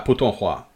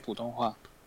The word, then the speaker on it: Putonghua: Yeah, Putonghua, Mandarin. – Yeah, Putonghua. Okay. – Putonghua. Yeah. – Oh. Okay. – Yeah. – Yeah, I have seen this written, but I was not pronouncing it like that.